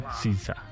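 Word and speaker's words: SZA: Y aquí dice, mira, fonéticamente, ¿qué dice aquí? – Sisa. Sisa. Sisa.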